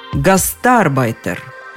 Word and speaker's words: гастарбайтер: Понятно, что оно сместилось ближе к концу слова «гастарбайтер», и все последние словари, и нормативные, и толковые, придерживаются именно такого ударения.